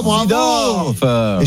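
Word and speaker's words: Seedorf: Oui, Zidorf. Et je peux pas lui. Est-ce que je l'ai bien prononcé ou pas? – Très bien.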